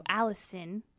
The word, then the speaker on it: allicin: So it's full of allicin, which it's spelled like A-A-L-L-I-C-I-N. So allicin.